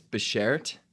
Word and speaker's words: beshert: It's beshert.